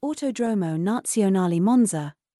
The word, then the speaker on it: Autodromo Nazionale Monza: I think that's how you say it. Actually, let me have Jarvis read this out, because I know I'm definitely butchering this. But ultimately, let me have Jarvis read this out right quick. – Autodromo Nazionale Monza. – All right, y'all got all that? Because I'm not pronouncing that.